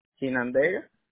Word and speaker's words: Chinandega: It's C-H-I-N-A N-D-E-G-A. Like a China, like China Giga.